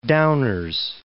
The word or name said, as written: Downers Grove